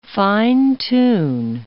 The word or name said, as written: fine-tune